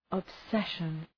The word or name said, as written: obsession